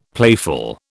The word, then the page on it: playful: British English